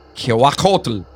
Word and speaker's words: Cihuacoatl: Scholars have pointed out similarities between La Llorona and, I'm going to destroy this word, Quihuacatl? – Kimi! – Wait, spell it. – C-I-H-U-A-C-O-A-T-L. Quihuacatl.